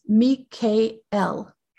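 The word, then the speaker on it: Mikael (מיכאל): And I don't know if you know this, but the original pronunciation of his name is Mikael. Originally, but now we call him Michael.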